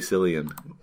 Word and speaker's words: Cillian: It's a C. It could be either one. – Type in Google how to say Cillian Murphy. – Cillian. – It's pronounced Cillian? All right.